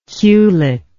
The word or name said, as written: culet